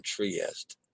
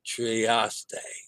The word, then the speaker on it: Trieste: I was wrong on Trieste. Trieste. AY pronunciation.